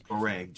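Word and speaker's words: Gorée: And so you bringing up Gori, is it Gori Island? Am I saying that right? Yes, Goree, G-O-R-E-E.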